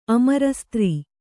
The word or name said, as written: ಅಮರಸ್ತ್ರೀ